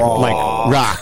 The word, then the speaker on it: RAWK: R-A-W-K. – Wow. I'll just send it to you guys. It's great. – R-A-W-K is rock? – Yeah, like rock.